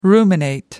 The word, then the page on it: ruminate: American English